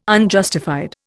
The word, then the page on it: Unjustified: Indian